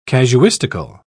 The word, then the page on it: casuistical: British English